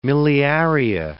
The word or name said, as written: miliaria